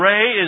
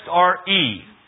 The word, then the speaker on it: Re: And Ray is next, R-E. Because it's the Latin spelling or pronunciation and not English. So it's Ray and not Re.